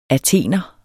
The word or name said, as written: athener